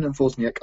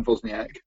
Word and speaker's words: Wozniak: And you can follow me at N Wozniak Art. That's NWOZNIAK Art. And you can also follow me on Instagram at Nathan Wozniak, which is spelled the same way because that's my name, no matter what you think.